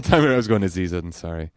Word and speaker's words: Ansari: Ansari.